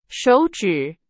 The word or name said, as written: 手指